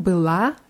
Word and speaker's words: была: Bei была ist die ganz deutlich auf a und у меня было много работы. Было ist auf ü, weil äh, sonst, wenn man было auch auf die letzten Silbe betont, dann hört es sich wie была an.